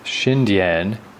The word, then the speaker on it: Xindian: Xindian, okay. Now I know, I was saying like Xindian, like trying to say it too fast. Like Indian with a sh.